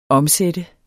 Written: omsatte

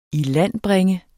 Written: ilandbringe

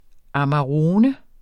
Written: amarone

